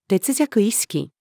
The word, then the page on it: 劣弱意識: female